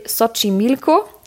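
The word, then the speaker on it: Xochimilco: Hier sind sämtliche Abenteuerparks und Freizeitparks und ja, da gibt es zum Beispiel Sochi Milko geschrieben mit X eigentlich, aber das wird wie ein Tsch ausgesprochen.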